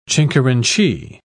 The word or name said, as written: chincherinchee